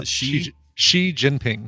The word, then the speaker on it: Xi Jinping: No, Xi Jinping.